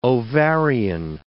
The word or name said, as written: ovarian